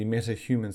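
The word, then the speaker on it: MetaHMN: I believe is pronounced as the MetaHuman series, although it's spelled M-E-T-A, and then humans kind of abbreviated as H-M-N.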